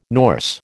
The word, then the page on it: Norse: American English